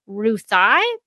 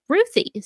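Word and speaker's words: Ruthye: And Tom King said, she's named after my 3-year-old niece, Ruthie. So that's how I pronounce it, but you be you.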